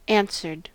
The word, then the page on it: answered: American English